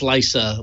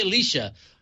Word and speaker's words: Leica: They're using the Sony lens for the main sensor, and the I can never pronounce Leica. – I want to say Leica.